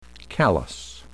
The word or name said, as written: callus